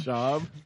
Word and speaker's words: Schaub: Matt Schaub.